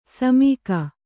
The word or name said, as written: ಸುಮುಖಿ